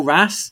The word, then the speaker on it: wrasse: Is it the wrasse? Am I pronouncing that right? The sling jaw wrasse? – Yeah, well, the sling jaw wrasse basically has a six bar mechanism.